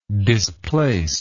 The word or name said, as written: displaced